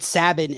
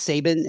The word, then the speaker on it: Sabin: I actually always called it Sabin, and then I had like three people tell me it's Sabin, and I wanted to sound professional in the air.